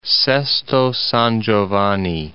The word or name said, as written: Sesto San Giovanni